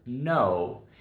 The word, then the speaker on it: know: The second and probably the most frequently used is the word know. The word is spelled K-N-O-W, but the K here is silent. You don't say the K, so it's just know.